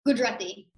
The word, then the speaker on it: Gujarati: Am I pronouncing that wrong? – Gujarati. – Gujarati.